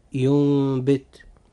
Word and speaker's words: ينبت: كلمة ينبت، هذه الكلمة جاء فيها حرف النون الساكن وتبعه حرف الباء، فهنا لابد أن يحدث إقلاب، فننطق هذه الكلمة هكذا ينبت.